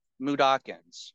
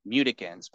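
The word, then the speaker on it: Mudokon: So I've always pronounced it Mudakins. – Okay. – But then I decided to like look up the correct pronunciation for it, and it was supposedly pronounced Mudikins. But I'm like, well, I've always pronounced it Mudakins. I'm gonna keep pronouncing it Mudakins.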